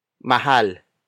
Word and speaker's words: mahal: Mura is spelled as M-U-R-A. In this context, it means cheap or inexpensive. Our last example is mahal. Mahal. Mahal. Mahal is spelled as M-A-H-A-L.